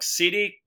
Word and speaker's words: Xidit: Well, yeah, that was Lords of Cydid. It's spelled X-I-D-I-T.